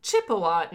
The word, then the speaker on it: Ojibwe: Some may have heard of the Chippewa Native Americans, and Chippewa is essentially a Western European pronunciation of Ojibwe by the English. It doesn't sound enough. Ojibwe.